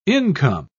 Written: income

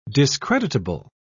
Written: discreditable

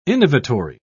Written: innovatory

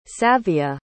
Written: savia

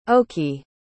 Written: okey

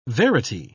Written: verity

